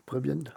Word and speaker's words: Prwin: Prévienne. Ah, bah, je sais pas, je l'ai c'est pas écrit. – Alors, ça c'est, alors ça s'écrit P-R-W-I-N. Ouais, Prévienne.